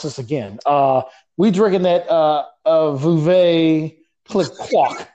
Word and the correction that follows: Veuve Clicquot: I know I am. I got, if anybody is French listening to this, sorry. Babe said, Veuve Clicquot. I messed it up again.